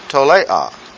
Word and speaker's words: tolea: And the word for worm, again, write it something like this, tole, make that a long A, tolea.